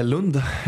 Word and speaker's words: Lund: Aus, wie spricht man das aus? Lund oder Lund? – Lund, ja.